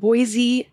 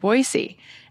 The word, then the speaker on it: Boise: I moved here a little over 10 years ago, and I came from Montana originally, another state, just one state away, and we pronounce it Boise as well with the Z.